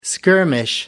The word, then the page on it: skirmish: American English